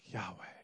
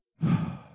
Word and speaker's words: Yahweh: But some rabbis have said the best way to pronounce it is just by breathing. Kind of sounds like Yahweh.